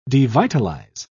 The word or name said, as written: devitalize